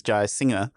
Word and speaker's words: Jayasinha: It's just there's an invisible G that my ancestors have dropped, but it needs to be pronounced because it's Jayasinha, like someone singing songs.